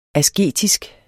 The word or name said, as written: asketisk